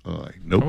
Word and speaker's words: gnocchi: G-N-O-C-C-H-I. Gnocchi? – I've always heard it pronounced gnocchi.